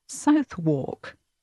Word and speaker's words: Southwark: Southwark is spelt S-O-U-T-H. W-A-R-K. So you might think that this place name would be pronounced Southwark. That seems logical. But no, actually this place name and this stop on the Jubilee line is called Southwark.